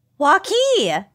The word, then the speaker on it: Waukee: A woman from Waukee, Iowa. Did I say that right? – Waukee! That is literally 10 miles from me.